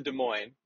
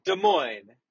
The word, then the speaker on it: Des Moines: And Des Moines is spelled D-E-S-M-O-I-N-E-S. So each of these two words ends in an S, but both of these S's are silent.